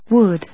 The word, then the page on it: wood: wˈʊd